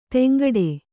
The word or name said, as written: ಧಿಂಕಿಡು